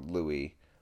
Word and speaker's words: Louis: Miles ahead of Lewis. Well, Louis.